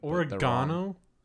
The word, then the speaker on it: oregano: People think they're like, oh, that's a proper Italian way to say it. – Oregano? – Yeah. – Sounds like a disease. – It is sounds that, like Leia's last name. – Organa? Is that really how it's pronounced? – No, it's just people are like, yeah, it's the European way of saying it.